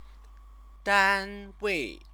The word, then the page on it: danwei: chinese